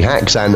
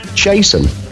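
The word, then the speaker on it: Haxxan: It's not Haxan. There's a little funny umlauty thing over the A. It's pronounced Chasen.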